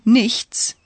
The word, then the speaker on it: nichts: Scusi, ich nix verstehen. Did the tourist mispronounce the word nothing, nichts? No, in everyday German it's often said like that.